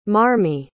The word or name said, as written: marmee